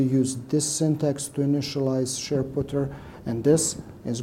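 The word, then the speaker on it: shared_ptr: These are pronounced SharePutter.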